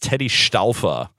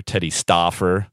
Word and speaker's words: Stauffer: His name was Teddy Stauffer, if you can pronounce German properly, or Teddy Stauffer for our friends here in the United States.